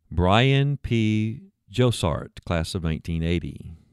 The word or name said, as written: Bryan P Jossart